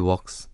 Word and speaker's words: walk: She walks this earth. 아, she walk. 네, 이렇게 발음해야죠. 네. 어릴 때부터 이 work와 work가 참 음, 헷갈렸어요. 중학교 때부터니까 벌써 한 30년이 됐는데도 아직도 구분을 그렇게 잘 못합니다.